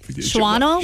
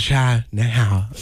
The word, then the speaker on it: Shawano: Shuano? – Shuano. Yeah, that's how everybody pronounces it when they first move here, because that's what it looks like. – Not me. Why did I say it? Sha-now.